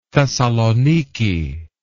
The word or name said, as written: thessalonike